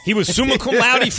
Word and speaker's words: cum laude: He graduated, how do you say it, cum laude? – There's let's see.